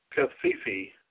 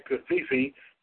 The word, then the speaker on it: covfefe: The new word Kafifi, that's the best way I can pronounce it, C-O-V-F-E-F-E, has been